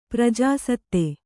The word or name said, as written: ಪ್ರಜಾಸತ್ತೆ